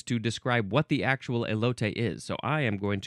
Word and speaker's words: elote: Now, if I didn't actually know the pronunciation of this word, I would have said elote, because that's how it's spelled in English. That's how it would be pronounced in English. but this is a Spanish word, elote.